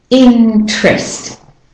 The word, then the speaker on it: interest: So you've got N, T, and R all together in a row. Interest. You hold on to the N a bit longer to get to the T. You leave out the syllable. Interest. Interest.